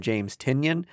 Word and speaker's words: James Tynion: Nice House on the Lake, DC Black Label comic from James Tynion. The fourth. I said that wrong, I'm sure. – No, you got it.